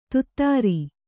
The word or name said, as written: ಧತ್ತೂರ